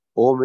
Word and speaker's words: Om: Om is 3.5 letters. Om. Some people say, Aum. No, it's wrong.